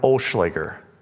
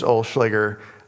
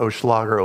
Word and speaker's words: Olschlager: I am an Olschlager. My last name is Olschlager. You may have struggled to pronounce it and say it. That's fine. You can call me whatever you want, Olschlager, Olschlager. That means, as an Olschlager, we spend a penny to save a dime.